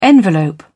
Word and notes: envelope: British English

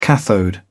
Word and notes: cathode: British English

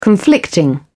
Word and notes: conflicting: British English

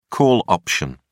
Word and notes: call option: British English

